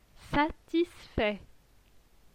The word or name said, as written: satisfait